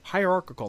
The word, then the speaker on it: hierarchical: Socially, they had rigid, Hierarchical. That's how you say it? That sounds wrong. – It sounds wrong, but it's right.